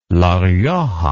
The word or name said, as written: La Rioja